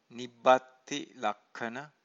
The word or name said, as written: nibbatti-lakkhana